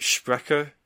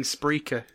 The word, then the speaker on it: Spreaker: How do you pronounce it? Sprecher? – I've just been saying Spreaker.